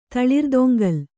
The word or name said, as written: ತಳಿರುತೊಂಗಲು